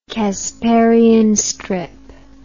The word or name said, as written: casparian strip